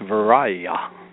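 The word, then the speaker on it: viriya: In the Pali, that is pronounced Viraya, energy.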